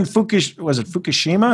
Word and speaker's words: Fukushima: Obviously, those were even Fukushima? Is that what I'm saying it right? – That sounds right.